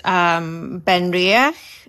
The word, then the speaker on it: Benriach: Benriach in Speyside, Glendronach in the Eastern Highlands, and Glenglassa on the coast.